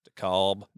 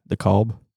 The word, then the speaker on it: DeKalb: How do you say that? – Decab. – DeKalb. – DeKalb.